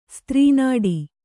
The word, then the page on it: ಸೂತ್ರನಾಡಿ: kannada; sītra nāḍi